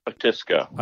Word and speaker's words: Otisco: I'm going to butcher the name again. Was it Otisco? O-T-I-S-C-O? – Otisco.